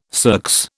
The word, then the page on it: circs: British English